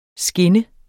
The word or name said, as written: skinne